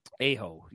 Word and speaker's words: Aho: I believe it's pronounced Aho, but Aho, yes.